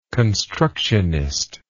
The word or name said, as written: constructionism